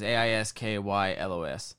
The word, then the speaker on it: Aeschylus: It's spelled Aeschylus. – Okay. – And the pronunciation is Aiskylos.